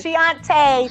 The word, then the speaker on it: Chianti: Help me with the pronunciation. – Chianti. – It's spelled C-H. – C-H-A-N-T-I.